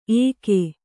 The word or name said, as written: ಏಕೆ